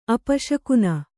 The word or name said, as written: ಅಪಶಕುನ